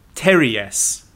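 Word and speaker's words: pteruges: He said, Evil's costume, Terries. And this is spelled Terruges.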